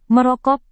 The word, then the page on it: merokok: Indonesian